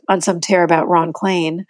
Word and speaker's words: Klain: And there's no one in Washington who has any confusion about how you say Ron Klain. It looks like rain and Spain and plane and Maine and vein, and it rhymes with all of those things.